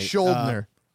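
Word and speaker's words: Schuldiner: So Chuck, I can even never pronounce his last name right. – Schuldner. – Thank you.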